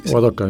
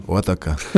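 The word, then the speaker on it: Ardagger: Gemeindepolitiker aus Adagermarkt. Ähm, spreche ich es überhaupt richtig aus? Wie, wie sagt man? Sie, Ordacker, ja. Ordacker. Als Mostviertler, ich bin kein, kein Mostviertler, ich kann es nicht richtig aussprechen.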